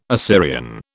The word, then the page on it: Assyrian: British English